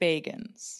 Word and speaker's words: Bagans: He pronounces it Bagans.